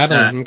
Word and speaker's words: Abilene: Yeah, Abilene, okay. – Am I pronouncing it right? – Yes, correct.